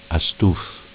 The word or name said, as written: A stuff